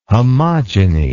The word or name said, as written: homogeny